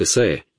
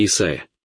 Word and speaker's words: Исаия: Пророк Исаия, или вы говорите Исаия? Мне всегда приходится объясняться. Я ходил в школу и работал в аспирантуре в Шотландии, и именно так произносили Исаию.